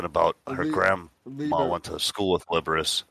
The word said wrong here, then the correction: Liberace: I'm going to drink now because of how badly I just fucking pronounced that. Liberus, and it's Liberace. All right.